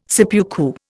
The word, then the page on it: seppuku: Indian